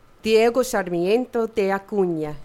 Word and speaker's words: Diego Sarmiento de Acuña: Diego Sarmiento de Acuña.